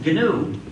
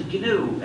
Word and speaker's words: GNU: So please pronounce it GNU. It's not new, but it still is GNU, and it will always be GNU.